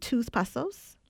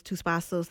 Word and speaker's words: Tus Pasos: Okay, so you have three tracks, and correct me if I pronounce them wrong. Is it Tus Pasos? – Yes, okay.